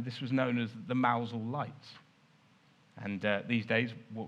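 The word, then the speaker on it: Mousehole: That's Mousel. Which is spelled Mouse Hole. All right. But it's so named because while the harbour itself, the basin, the water that you can there see a dragon in, but normally has boats, that's a decent size. But the gap through which the boats have to get into the harbour is very narrow, very small, hence Mouse Hole. Just pronouncing Cornwall, Mosel.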